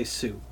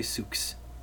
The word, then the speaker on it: Sioux: At least I know how to say Sioux. A lot of people say Sooks because it's spelled S-O-I-U-X.